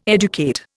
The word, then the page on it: educate: Indian